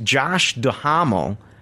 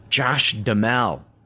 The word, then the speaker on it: Duhamel: And I always thought it was pronounced Josh Duhamel, and they put in here pronunciation Josh DeMel. I never knew that.